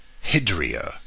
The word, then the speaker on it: hydria: So we read, "And there were 6 stone urns," or water jars, the Greek word hydria, as I would pronounce it.